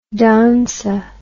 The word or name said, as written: danza